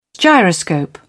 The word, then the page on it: gyroscope: British English